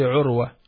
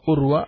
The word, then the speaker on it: Urwa: The name Urwa, it's actually Urwa, not Urwa, but it's been written in English with a U, because there is no Ayn in English. But the proper pronunciation is Urwa.